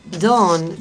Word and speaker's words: Dawn: Dawn, claro, Dawn, sí. Se pronuncia así el amanecer, y la banda, el alba.